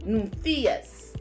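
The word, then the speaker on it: nymphios: and that word is pronounced Nymphaeus.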